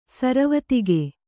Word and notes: ಸರವತ್ತಿಗೆ: saravattige

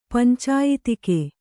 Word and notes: ಪಂಚಾಯತಿಕೆ: pancāyatike

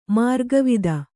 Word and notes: ಮಾರ್ಗವಿದೆ: mārgavide

